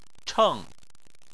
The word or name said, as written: cheng4